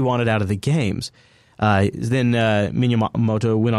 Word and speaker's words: Miyamoto: They were with an interview with how do you say his last name? Miyamoto? I can't remember. I always butcher it. – Miyamoto. – Thank you. That's what it is.